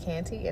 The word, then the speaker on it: Kante: You can follow me on Facebook as well at Fonta Kante, and it's spelled F-A-N-T-A, last name K-A-N-T-E.